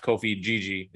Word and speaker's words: Djidji: And if you listen to the back three they played in that match, Ricardo Rodriguez, Buongiorno, and I think it's pronounced Kofi Gigi, D-J-I-D-J-I.